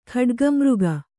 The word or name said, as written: ಖಡ್ಗಿಮೃಗ